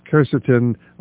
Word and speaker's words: quercetin: We talked about quercetin. You could pronounce it quercetin? – Quercetin.